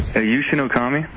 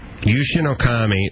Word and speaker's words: Yushin Okami: Yushin Okami. – Yushin Okami, and his record is what? – It's 20-3.